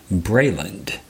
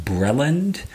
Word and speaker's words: Breland: As a result, the people of that land, in my opinion, call it Brayland. Now, uh, it is. I have also heard it pronounced Breland or Breland. And in my opinion, people, uh, that's perfectly valid.